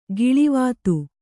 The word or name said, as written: ಕಳಾವತಿ